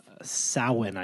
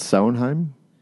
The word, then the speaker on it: Samhain: So it's widely believed that this, you know, stems from Celtic traditions, particularly harvest festival known as Samhain, I believe is how it's pronounced, spelled Samhain. – Isn't that Samhain? – I think it's pronounced Samhain.